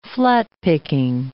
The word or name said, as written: flat-picking